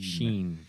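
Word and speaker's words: Shein: Shine? – Sheen.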